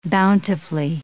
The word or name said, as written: bountifully